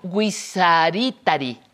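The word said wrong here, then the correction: Wixaritari: Guisaritari. – Viraritari. – Viraritari, ya decía yo.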